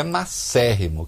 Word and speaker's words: macérrimo: Ou é magríssimo, ou é macérrimo, que magro em latim é macer.